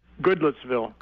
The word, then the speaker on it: Goodlettsville: Goodlettsville.